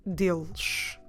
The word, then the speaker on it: deles: Don't forget to drop your last E of the word and make sure you pronounce that last S as a sh sound. Deles. You're stressing the first syllable? It is the second to last, that's normally how we count them. So it's the second to last syllable.